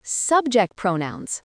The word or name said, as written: subject pronouns